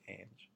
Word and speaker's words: Ange: Her name is Ange Hime, because she's Italian, and I asked her, and like, did I say that right, or that's butcher with my like American accent. She's like, it's okay, it's fine, but it's Ange. So if you guys are looking for her on Instagram, that's how you say her name.